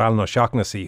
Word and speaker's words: Alan O'Shaughnessy: And also Alan O'Shaughnessy, who's owner at Taihu Restaurant in Limerick.